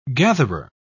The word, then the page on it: gatherer: British English